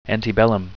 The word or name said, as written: antebellum